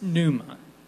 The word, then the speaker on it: pneuma: The Greek word for Spirit is pneuma, with a silent P at the beginning.